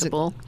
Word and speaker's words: Kabul: Is Kabul.